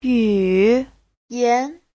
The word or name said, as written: yu3yan2